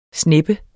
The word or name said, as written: SNEPPEN